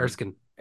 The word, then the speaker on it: Erskine: Erskine. – Erskine, is it? – Yeah, I think Yeah.